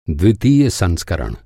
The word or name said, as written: संस्करण